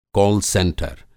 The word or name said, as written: कॉल सेंटर